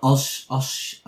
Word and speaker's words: Osumex: Yeah, it's a company called Osumex. So it's O-S... – How do you spell it? – O-S-U-M-E-X. Osumex. O-S-U-M-E-X.